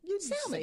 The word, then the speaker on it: salmon: It's S-A-L-M-O-N. So I called it salmon. – Say the L. – I saw it. But it's salmon. – Salmon. – S-A-M-U-H-N. Salmon. – Sure. The L is silent.